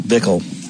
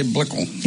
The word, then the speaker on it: Blickle: It's not Bickle. How do you spell that? It's Blickle, isn't it? – Blickle. – Blickle. – Yeah, I'd say Blickle. – Yeah, Blickle.